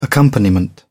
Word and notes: accompaniment: British English

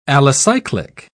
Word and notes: alicyclic: British English

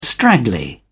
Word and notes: straggly: British English

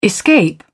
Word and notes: escape: British English

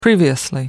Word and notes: previously: American English

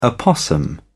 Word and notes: opossum: British English

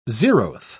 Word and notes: zeroth: British English

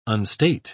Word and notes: unstate: British English

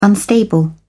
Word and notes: unstable: British English

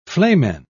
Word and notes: flamen: British English

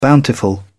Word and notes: bountiful: British English

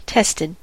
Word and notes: tested: American English

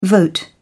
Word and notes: vote: British English

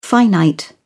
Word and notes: finite: British English

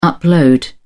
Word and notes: upload: British English

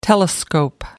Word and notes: telescope: American English

